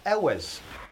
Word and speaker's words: Elwes: I've never known Elwes.